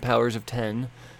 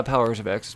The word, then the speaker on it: Powers of X: So we know we got House of X and Powers of 10, is what it actually is now. It's not Powers of X, it's Powers of 10.